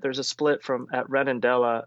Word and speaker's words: Redondela: Redondela, yeah. – so and that goes up, continues down the central, but then once you get into the day's walk after Redondela into Pontevedra, it deviates.